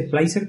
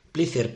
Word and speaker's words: Plyzer: Sí, hay que decir que sí, dice Plicer, pero se escribe Plicer con Y Plicer, y con Z. Sí. – Plicer, P-L-Y-Z-E-R. – Eso es. Bueno, pero nosotros como sabemos inglés, pues decimos Plicer.